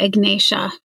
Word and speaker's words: Ignatia: Ignatia. – Yeah, Ignatia.